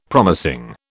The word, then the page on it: promising: British English